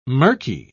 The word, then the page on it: mirky: British English